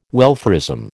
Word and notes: welfarism: American English

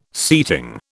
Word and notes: seating: British English

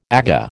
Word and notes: Aga: American English

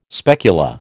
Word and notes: specula: American English

